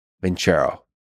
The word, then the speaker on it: Vincero: Vincero, by the way, is spelled with no H, just C. Vincero.